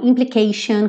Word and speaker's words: implication: Então, implication, complication, você consegue ver aí, implicação, complicação. Então, tem um padrão de pronúncia para essas palavras, que esse T sempre vai ser sh, sh, sh, esse som aqui, tá bom? Implication, complication. Alright? Então vocês vão ver muito esse T-I-O-N aí pintado de verde nessa música.